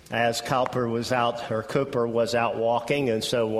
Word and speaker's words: Cowper: And I know it looks like Cowper, but I think usually are pronounced a Cooper.